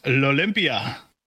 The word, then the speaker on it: L'Olympia: And yeah, the venue is L'Olympia. – Oh, that's how you say it.